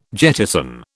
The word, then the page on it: jettison: British English